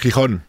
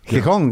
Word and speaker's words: Gijón: Gijón. – Gijón, yeah.